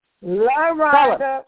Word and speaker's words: LaRonda: We'll try to steer people to that, Rwanda. – Yes, and my name is LaRonda. – Spell it, please. – LaRonda. I'm sorry. – L-A, capital R-O-N-D-A,